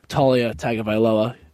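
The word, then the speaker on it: Taulia Tagovailoa: I think the Terps cover the spread with big games from Talia Tagovailoa.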